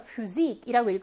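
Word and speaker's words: Physik: 폐하는 여러분 F 발음이에요. 그래서 Physik이라고 읽으셔야 하고요.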